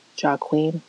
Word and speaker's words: Joaquin: Joaquin. That's how you say his name.